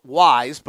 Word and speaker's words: Wyze: It's called Wyze, but it's spelled W-Y-Z-E. It's packed with premium features.